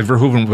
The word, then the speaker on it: Verhoeven: Verhoeven.